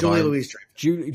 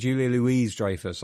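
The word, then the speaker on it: Julia Louis-Dreyfus: Is it Julianne Dreyfus? – Yes. – Yep. God, Julia Louise Dreyfus. – Julia Louise Dreyfus.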